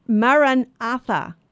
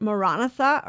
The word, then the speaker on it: Maranatha: And so we're looking here at this is it Maranatha or Maranatha? And it's funny because up, up until recently I had all, only heard it said Maranatha. So I didn't realize there was another way to pronounce it. It turns out that um, Maranatha, Maranatha is, a, is an Aramaic word.